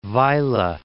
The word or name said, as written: Vejle